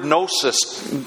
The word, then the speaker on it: gnosis: They were the people who, it comes from the Greek word gnosis, G-N-O-S-I-S, sort of kind of word, silent G at the beginning.